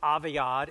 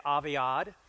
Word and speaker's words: Aviad: Uh, it's pronounced Aviad. Everyone say Aviad.